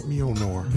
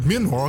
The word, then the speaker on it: Mjolnir: Uh uh, uh, uh, I can never say that hammer uh, Mjolnir. Mjolnir, okay, I was saying that right.